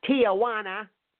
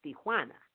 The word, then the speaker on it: Tijuana: It's not Tijuana. It's Tijuana.